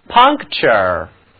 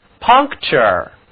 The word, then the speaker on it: puncture: 这个词它的读音挺怪的，其中呢字母 N 读作后鼻音 T-U-R-E 则读作 chur。来跟我读 puncture puncture。